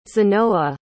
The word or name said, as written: zenoa